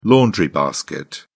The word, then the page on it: laundry basket: British English